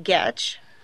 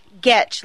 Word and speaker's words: Getch: My last name is pronounced Getch. – Gouch. – Getch, like I'm going to get you. – Oh, Getch.